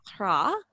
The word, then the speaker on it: Rat: Our next article actually came from a reader and listener of the newsletter and podcast, Quentin Ra. He's French, so it's spelled R-A-T, but I looked up, it's pronounced Ra.